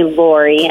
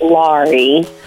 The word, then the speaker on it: Laurie: It's not a big deal, but many people call me Lori, and it's pronounced Laurie, which I'll answer to anything.